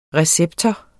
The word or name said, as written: receptor